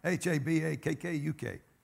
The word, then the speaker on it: Habakkuk: H A B A K K U K.